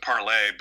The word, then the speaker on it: Parler: So I also joined Parler recently. I guess they pronounce it Parlay,